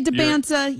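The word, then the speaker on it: Dybantsa: I'd say DeBonsa. DeBonsa. – I know. Can we get one pronunciation, please? Because I've heard it both ways for 2 years. So DeBonsa. – DeBonsa. We're going DeBonsa.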